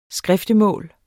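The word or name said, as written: SKRIFTEMÅLS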